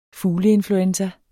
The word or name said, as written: fugleinfluenza